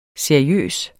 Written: seriøs

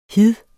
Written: hid

